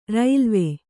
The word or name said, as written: ರೈಲ್ವೆ